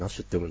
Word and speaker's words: nosh: ナッシュ、これナッシュって読むんだ。